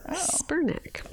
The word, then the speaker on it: Spurnak: How do you spell that? S-P-U-R-N-A-K. – Oh. Spurnack.